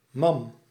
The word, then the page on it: mamelle: France; /ma.mɛl/; French